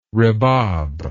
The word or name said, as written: rebab